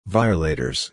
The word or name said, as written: violators